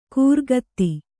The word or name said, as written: ಖುರಗತಿ